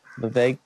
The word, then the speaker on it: Vivek: what, how do you say his name? Vivek? – Vivek. Vivek. Ramaswamy.